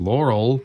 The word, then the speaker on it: Laure: And we had that contrast immediately because the first person, the contact person, was Laure, which is spelled like Laurel, with no L at the end. – Right. So we were confused. Is it Laura? Is it Laurel? No, it's Laure.